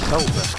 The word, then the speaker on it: solder: How do you pronounce the word S-O-L-D-E-R? – Soldier. – Soldier, okay. Although over here we say solder. – I know, but you're straight. – Don't tell my kids. – Solder. I'm from the East Coast saying solder. – Yeah, Sada. Sada and Gamora. Yeah, Sada. We like to Sada shits around here. Yeah. Shit up. We Sada. – Solder. – Solder. To rhyme with shoulder.